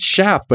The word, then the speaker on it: Schaff: Oh, it's Clint Schaff, but it rhymes with off.